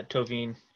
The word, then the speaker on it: Thauvin: Anyway, a right wing, you you'd like somebody like uh, like it, I don't even know the proper pronunciation. I feel like it's Tovin, but um, Laurent Thauvin. I yeah, Tovin. It's French.